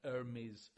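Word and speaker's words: Hermès: Yes, it's true that if you're trading in light, high-value items, like Hermes scarves. How did you like that pronunciation of the silent H?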